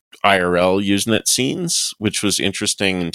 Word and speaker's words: Usenet: So, moving along from Usenet, am I pronouncing it correctly? – Yes.